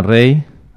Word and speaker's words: Rhei: Y os recordamos también que estamos con Sofía Rey. Bueno, vamos a decir que en Rey se escribe con E, o sea, es R-E, perdón, R-H-E-I latina, ¿vale?